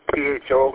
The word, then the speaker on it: pho: WB80, I see on my tablet that Walmart carries instant chicken full, PHO. I know you pronounce it different than I do. How would I pronounce that? Pho.